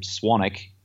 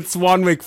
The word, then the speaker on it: Swanwick: It's funny, Brian, I actually pronounce my name Swanick, but the Americans don't really understand the second silent W. So I've been calling it Swanwick for about 18 months since I bought your glasses.